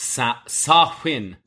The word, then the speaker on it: Samhain: A bunch of goofy ass white people get too far into their neo-pagan celebrations of Samhain, pronounced Sa-Win, Paul Rudd, and decide to break free local noted psychopathic killer Michael Myers.